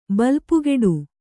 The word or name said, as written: ಬಲ್ಪುಗೆಡು